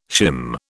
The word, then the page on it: shim: British English